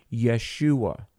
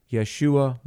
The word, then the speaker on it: Yeshua: And it's true, you know, we often say Jehovah God, but there is not a J in Hebrew. It's more like a Y, like Yahweh. And so when we talk about Jesus, oftentimes you'll hear people say kind of the Hebraic pronunciation, Yeshua, Yeshua Mashiach, Jehovah saves, Jesus Messiah.